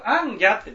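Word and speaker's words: 行脚: 強語とは読まずに偶語と、ね、全国アンギャって言ったらアンギャっていうのはアンっていうこういう字を書きますよね。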